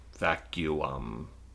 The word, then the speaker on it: vacuum: Like, no, you don't say the U twice just because it's two U's side by side. Vacuum.